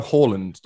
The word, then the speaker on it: Haaland: You go Haaland, don't you? – Yeah. – Which is the correct... – Yes. – ...pronunciation.